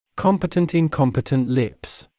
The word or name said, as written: competent/incompetent lips